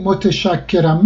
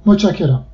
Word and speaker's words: متشکرم: هیچ کس نمیگه متشکرم مگر اینکه بخواد خیلی لفظ قلم حرف بزنه، بگه متشکرم، ولی به طور عادی متشکرم. ت تلفظ نمیشه در اونجا و چه تلفظ میشه و ش هم تلفظ نمیشه.